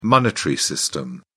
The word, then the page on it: monetary system: British English